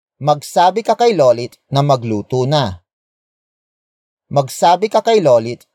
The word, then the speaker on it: magluto: And that adverb is magluto. Magluto. Magluto. Magluto is spelled as M-A-G-L-U-T-O.